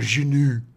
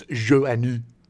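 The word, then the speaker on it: Joanou: Sure, and a lot of you probably have been pronouncing it Phil Joannou, as I have been for the last 40 years. – Yeah. – Not knowing that it was Joannou.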